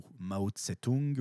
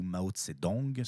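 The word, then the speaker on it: Mao Zedong: Et euh, bah, dans mes explications, euh, j'ai parlé de, beaucoup de choses, et entre autres d'un certain Mao, Mao Tse-tung ou Mao Tse-dong, suivant la prononciation que vous utilisez.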